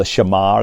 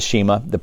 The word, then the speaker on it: Shema: And it comes as a reflection on the faith statement of the Jewish people, the Hebrew people, which is called the Shema or the Shema. You pronounce it different ways, but it's in Deuteronomy 6.